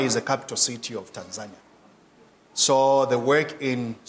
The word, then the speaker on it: Tanzania: I understand that, I want to say Tanzania, that's how we pronounce it, Tanzania.